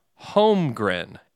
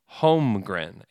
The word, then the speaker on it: Holmgren: Holmgren, H-O-M-E-G-R-I-N, is how it's supposed to be pronounced, which I thought is kind of interesting.